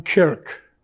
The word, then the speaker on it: kirk: But remember also that the Scots had their own church, or as they pronounce the word in Scotland, their own kirk. Okay, so if you've seen that word kirk, K-I-R-K, as in Captain Kirk, you could just call him Captain Church if you want to.